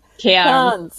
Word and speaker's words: Cairns: Sorry, ich meine, oder wie wird es ausgesprochen? – Cairns. – Genau. Cairns. Und jetzt alle.